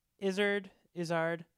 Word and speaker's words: Izzard: Izzard? – Izzard.